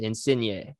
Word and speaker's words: Insigne: what should we know about Lorenzo Insigne with an accent aigu at the end? Insigne. – I don't know if it actually has that, but that's how you say it.